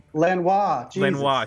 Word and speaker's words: Langlois: And then Paul Langlois. I think that's how you pronounce his name. – Lanois, sorry. It's French.